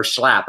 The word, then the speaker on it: Schlapp: Uh, but the, uh, on Wednesday, Richard Grenell appeared with Matt Schlapp at a, or Schlapp, I believe is the way you say his name, at a press conference in front of the Nevada Polling Center.